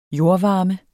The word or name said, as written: jordvarme